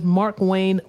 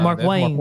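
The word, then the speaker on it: Markwayne: The Washington Post reported that Republican or Representative Mark Wayne Mullen, That man had two names. – You ain't say that right. Wayne? You said it with a space in between. – Yeah, Mark Wayne.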